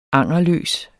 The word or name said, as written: angerløs